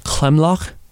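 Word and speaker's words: Klimlag: So in Afrikaans, it's pronounced Klimlach. So it's Clim-lach.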